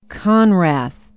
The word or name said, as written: conrath